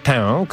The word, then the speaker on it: tę: W tej sprawie nie wiedziałem, że forma tę, którą oczywiście przesadnie, hiperpoprawnie wymawiam nosowo, tak zajmuje naszych drogich słuchaczy. Michał, tę nie jest chyba hiperpoprawne, ale żeby nie wychodzić na snoba, to można to wymawiać bez przesadnego akcentowania, po prostu te.